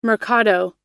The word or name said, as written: mercado